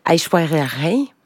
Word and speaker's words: Aishwarya Rai: Aishwarya Rai.